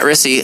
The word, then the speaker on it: Rissi: Yeah, if you just look up Matt Rissi, last name is spelled RISSI.